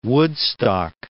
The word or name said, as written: Woodstock